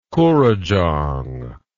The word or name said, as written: koorajong